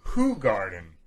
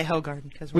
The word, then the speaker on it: Hoegaarden: If you want to pronounce it correctly, it's Hoegaarden. – Hoegaarden. – But we just say Hoegaarden.